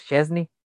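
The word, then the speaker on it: Szczęsny: Eh, y pues sí, como tú dices, la duda aquí es nosotros lo, lo, te lo decimos, lo pronunciamos, Chesney, que bien si el polaco no se ha ganado en sí la titularidad en algunos partidos, pero da mucho de qué dudar.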